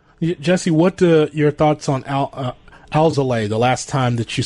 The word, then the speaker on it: Alzolay: It's now Alzolay. Alzolay is the correct pronunciation. We all were saying Alzolay. If Len Casper is saying it, it must be gospel. So it's Alzolay for now on.